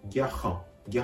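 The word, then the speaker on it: garant: 発音のポイント、これは後藤のあるギャハン、ギャハンのある発音です。 R の発音は、この場合では、うがいをするような、はという仕草に、ハヒフヘホの、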